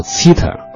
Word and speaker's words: Zither: 这个乐器呢，德语叫 Zither，翻译成奇特尔是相当接近它的发音的。